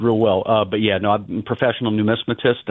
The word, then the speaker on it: numismatist: You are an affirmed numismatist.